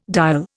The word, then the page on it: diol: Indian